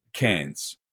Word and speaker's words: Cairns: Cairns. – Cairns. – As in like a Coke can.